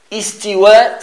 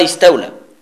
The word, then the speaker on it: istiwa: Istiwa, c'est istiwa, c'est pas istaula.